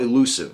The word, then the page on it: elusive: American English